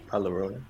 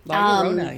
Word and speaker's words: La Llorona: La Llorona. – Thank you. – La Llorona, yes.